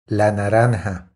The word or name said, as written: La naranja